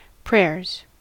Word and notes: prayers: American English

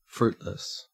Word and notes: fruitless: Australian English

